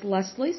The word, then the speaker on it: Lesley: And just to give a proper introduction, my name is Lesley Sullivan. That is L E S L E Y. And I spell that because a lot of people misspell my name and they put I E.